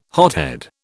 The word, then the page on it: hothead: British English